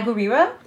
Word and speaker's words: Gurira: Now we got Danai Gurira.